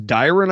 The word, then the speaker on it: Dyron: Jimmy, you may know him as Dyron, I believe, but we know him as Flynn Hendricks.